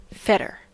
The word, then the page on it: fetter: American English